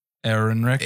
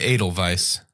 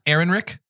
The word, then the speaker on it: Ehrenreich: Do you know how to pronounce his last name? – Ehrenreich. – Edelweiss. – Ehrenreich. – I think that's so. Right. – It looks like Ehrenreich.